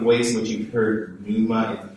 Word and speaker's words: pneuma: Someone who meant pneuma. I like to pronounce the P. Maybe you just heard it as pneuma.